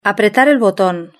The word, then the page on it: apretar el boton: European Spanish